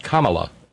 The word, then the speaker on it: Kamala: In her memoir, she writes, first, my name is pronounced Kamala, like the punctuation mark.